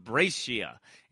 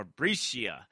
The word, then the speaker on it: Brescia: Arnold of Brescia. And then there's a few different pronunciations to it, but I'll just say it like that because that's how they say it. Or Brescia. Or Brescia.